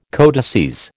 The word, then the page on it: codices: American English